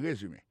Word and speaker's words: résumé: It's called a résumé. Alors là encore, si on a parlé d'accent, accent tombé sur le premier syllabe.